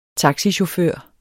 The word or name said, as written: taxichauffør